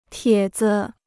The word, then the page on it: 帖子: tiě zi